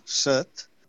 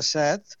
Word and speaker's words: set: Sí, aquí la major part de les illes es pronuncia en vocal neutre, set. En canvi, en català central es pronuncia en veu oberta, set. I en català occidental es pronuncia set. És a dir, que en català tenim les tres pronunciacions. Per tant, a les illes, a la major part, diferenciam set, nombre, i set, ganes de beure.